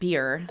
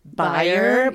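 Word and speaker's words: bier: B-E-I-R. – Oh, okay. – Yes. – Yeah, but you pronounce it beer. – Okay. – A lot of people say buyer, but it's not right. It's Elizabethan. It's pronounced beer.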